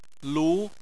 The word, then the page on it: lu: 2